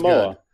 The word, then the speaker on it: Koramoah: Jeremiah Owasu Koromora. – Hey, sounds Koramoah.